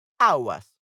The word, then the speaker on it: agua: So, la palabra agua empieza por la A tónica, la sílaba que se pronuncia más fuerte, agua. Así que si utilizáramos el artículo la, tendríamos que decir la agua, que no suena bien.